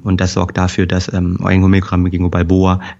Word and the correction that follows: biloba: äh, Bingo, sag ich auch schon Balboa. – Bilabo, das, ich habe das, hab das 25 Jahre lang falsch gesagt, das muss mich da, ja. Äh, muss mir das abtrainieren. Bilboa, genau. Bilbao. Biloba. – Biloba, genau. Biloba. Genau. Gingo.